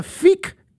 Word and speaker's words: Fiqh: It's called the Fiqh Council of North America. Fiqh is spelled F-I-Q-H.